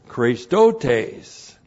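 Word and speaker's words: Christotes (χρηστότης): Once again, n plus the dative of means, by means of Christotes. C-H-R. Long E, S, T, O, T, long E, S, Christotes.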